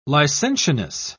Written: licentiousness

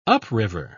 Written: upriver

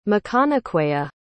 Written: Maconaquea

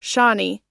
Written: shawnee

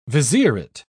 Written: vizierate

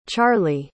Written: charlee

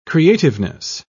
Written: creativeness

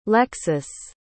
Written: lexus

